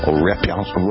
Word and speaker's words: rapprochement: You hear me trying to pronounce it? Rapprochement. – It is rapprochement. What is the word again? – Rapprochement. – Rapprochement. – Yes.